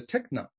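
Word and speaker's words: tekna: And there's the other word, the child, and is in the original language is pronounced as a techna.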